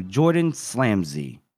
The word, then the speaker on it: Jordan Slamsley: And for our guest today, we'll be talking to Jordan Slamsley.